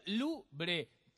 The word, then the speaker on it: Louvre: Louvre. Se pronuncia Louvre. Tú deberías saberlo y no yo.